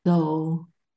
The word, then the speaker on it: Tao: Tao? How do you pronounce that? – Tao. – Tao. Thank you.